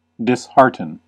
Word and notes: dishearten: [dɪs.ˈhɑɹ.tən]; /dɪsˈhɑː(ɹ).tən/; /dɪs.ˈhɑː.tən/

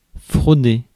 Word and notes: frauder: France; [fʁɔ.de]

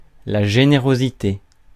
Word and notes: générosité: France; IPA: [la.ʒe.ne.ʁɔ.zi.te]

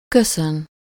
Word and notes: remercier: France (Paris); /ʁə.mɛʁ.sje/

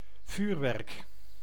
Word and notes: Feuerwerk: /ˈfɔɪ̯ɐvɛʁk/; /ˈfɔɪ̯ɐˌvɛʁkə/

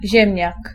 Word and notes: ziemniak: /ˈʑɛmɲak/; /źẽmʹńak/

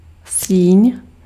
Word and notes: hall: American English; /hɔl/

